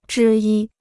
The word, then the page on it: 之一: zhī yī